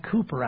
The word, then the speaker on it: Cowper: The author of that, William, we pronounce it Cooper, actually, although it looks like Cowper, originally wrote it, "There have I, though vile as he, washed all my sins away."